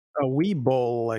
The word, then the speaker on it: Webull: It's W-E-B-U-L-L. – Webull, I guess it would be. – Yeah, right, Webull.